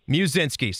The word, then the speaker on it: Muszynski: Is your last name pronounced Muszynski? Do I put the mu on over there, or how do you say it? Yep. Give me the exact saying. – Yeah, that's right. It's Muszynski. – Muszynski.